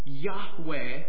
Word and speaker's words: Yahweh: But when he's revealing his personal name here, "I am," Yahweh is how it's pronounced, he's showing us how he is a God who is close.